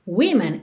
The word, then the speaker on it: women: Woman é W-O-M-A-N. Women é W-O-M-E-N. Ou seja, oficialmente, na escrita, o que muda é a segunda sílaba, mas na pronúncia é a primeira sílaba que muda.